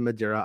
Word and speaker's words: Madeira: Last week, it was revealed that an unknown Star Wars project was set to film at Madeira Island, Portugal. Do you have any idea if I'm saying that right? – Madeira Island, okay.